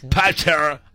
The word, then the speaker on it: Panettiere: Fallece Hayden Panettiere. ¿Sí se dice así? – Panettiere.